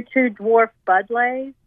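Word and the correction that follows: Buddleia: Budleias? – Oh, yeah.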